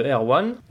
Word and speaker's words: R-One: Non, bah là en fait on va passer à un titre, euh, donc une production de, de Air One. 1 ça s'écrit R-1, parce qu'on pourrait croire qu'il est, qu'il est breton.